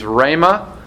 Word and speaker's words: rhema: The Greek term that is used is rhema. Uh, R H E M A is how you would spell it in English.